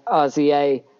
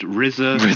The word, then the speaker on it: RZA: And finally, when you think of producers, Wu-Tang had RZA, NWA had Dr. Dre, and Bad Boy Records had Puff Daddy, but the CDP has the one and only producer. – Yeah, hi, it's pronounced RZA. – RZA.